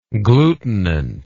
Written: glutinant

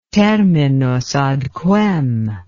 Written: terminus ad quem

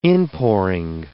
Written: inpouring

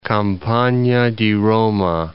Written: Campagna di Roma

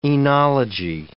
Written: oenology

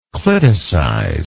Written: cliticization

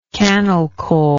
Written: cannelcoal

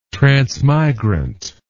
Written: transmigrant

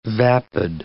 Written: vapid

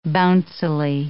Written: bouncily

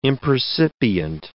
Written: impercipient

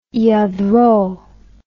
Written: Lladró

